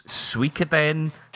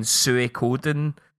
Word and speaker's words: Suikoden: It's Suikoden. I hear people also saying Suikoden. But aye, why did you pick Suikoden or Suikoden or whatever? Suikoden 2 for me.